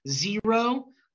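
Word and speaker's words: Xero: Zero, which is spelled with an X, it's X-E-R-O.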